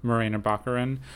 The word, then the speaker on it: Morena Baccarin: So I did want to talk about Morena Baccarin, or you looked up how to pronounce her name. – Morena Baccarin.